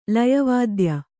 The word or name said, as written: ಲಯವಾದ್ಯ